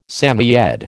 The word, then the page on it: Samoyed: American English